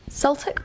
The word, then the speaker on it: Celtic: You've also got the Celtic people. Is that how you say it? – Mm-hmm.